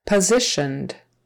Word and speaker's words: positioned: Syllable stress on the second syllable. Positioned. That S in the middle is pronounced like a Z. Positioned. Uh, SH sound for the TIO, positioned, and that ED on the end is just a D, positioned.